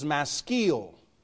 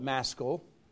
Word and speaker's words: maskil: Psalm 32 is the first of 13 psalms termed, and if I get the pronunciation wrong, forgive me, but the pronunciation guide I have says maskil. Maybe you call it maskil.